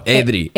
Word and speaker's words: Edre: I know I say your name wrong. – Oh, Adri.